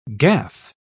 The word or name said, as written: gaffe